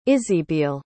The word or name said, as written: isibeal